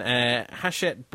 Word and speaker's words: Hachette: So there is, at this stage, it's sort of an ongoing saga between Hachette Books, I believe that's how you pronounce it. I've been told it's a Frenchy name. I called them Hachette, but apparently that's wrong.